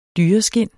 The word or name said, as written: dyreskind